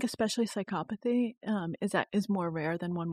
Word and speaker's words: psychopathy: Psychopathy. – Psychopathy.